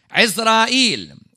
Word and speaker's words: Ezra'il: They were the servant of God, which would have been changed from Syriac into Arabic with the pronunciation that we see today of Ezra'il.